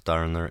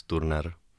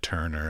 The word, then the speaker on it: Turner: In Czech, we pronounce it in Czech. So it's not Turner, it's Turner. – I know. I would have said Turner, actually.